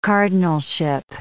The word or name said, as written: cardinalship